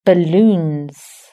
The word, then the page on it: balloons: British English